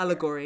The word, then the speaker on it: allegory: And I think the other thing that I got was basically, and I can never pronounce this word, is it allegory or allegory? Help me out. – Allegory.